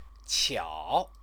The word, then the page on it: qiao: Chinese